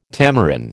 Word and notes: tamarin: American English

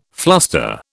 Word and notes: fluster: British English

